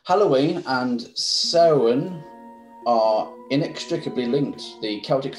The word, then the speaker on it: Samhain: You know with Halloween, and you know, is it pronounced Soan? – Soan? – The S-A-M-H-A-I-N. – Yep, Soan.